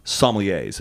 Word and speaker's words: sommelier: It follows four or five guys who are training to become sommeliers. Am I pronouncing that right? – Yeah. – It's always one of those sommelier. – Words. Sommelier. But I always want to add a little French to it.